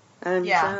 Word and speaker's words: Anzhou: I pronounced it Anjo. – Anjo is how he pronounced it. – Oh, I've not written it phonetically. It's A-N-Z-H-O-U. Anjou? Oh, that makes sense. – Anjou, yeah.